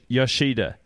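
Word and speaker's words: Yoshida: Emily Yashoda. Good stories remind us of who we want to be in Tone Henry. – Yashida. She's a great critic. – Oh, I beg your pardon for the pronunciation.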